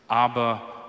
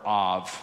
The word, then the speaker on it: Ab: The Spirit of Jesus enters into our hearts, and we cry out this amazing statement, Abba, Father. In Hebrew, the word for father is Ab, or Av, sometimes pronounced.